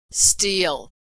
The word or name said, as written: steal